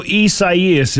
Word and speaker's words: Isaias: So Isaias is the correct pronunciation of it.